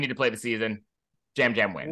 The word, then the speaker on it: Yam Yam: Abe was already confused why it's not Yam Yam, but I promise you it is Jam Jam. – Jam. – It is pronounced Jam. It is pronounced Jam Jam.